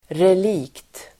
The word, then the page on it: relikt: [rel'ik:t]